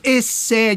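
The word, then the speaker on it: essais: não sei pronunciar ensaios em francês, como seria, mestre, por favor? Esse. esse. – Então, os famosos essé de Michel de Montaigne.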